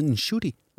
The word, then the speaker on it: Nshuti: 15, tror jeg, at Nkuti Gadra, han er den 15. – Ja. – Nå, 15. Og jeg har siden sidst lært at udtale hans navn. Han hed ikke Nkuti, han hed Nshuti.